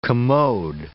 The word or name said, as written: commode